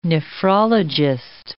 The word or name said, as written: nephrologist